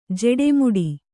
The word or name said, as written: ಜೆಡೆಮುಡಿ